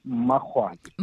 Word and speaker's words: Makgoale: Yes, my surname is pronounced with a double M. It's Mahwale.